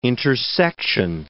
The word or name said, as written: intersection